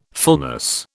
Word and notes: fullness: British English

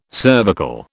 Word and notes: cervical: British English

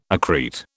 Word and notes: accrete: British English

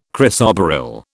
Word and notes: chrysoberyl: British English

